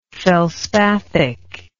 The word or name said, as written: felspathic